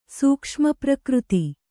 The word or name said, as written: ಸೂಕ್ಷ್ಮಪ್ರಕೃತಿ